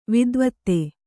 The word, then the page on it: ವೇದವೇತ್ತ: vēdavētta